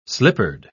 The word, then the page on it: slippered: British English